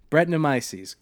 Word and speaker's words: Brettanomyces: Brettanomyces.